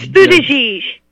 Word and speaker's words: Buttigieg: Buttigieg. – It's Buttigieg. – Buttigieg.